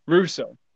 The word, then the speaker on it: Rousseau: How did you say that? Rousseau. Yeah, Gregory Rousseau.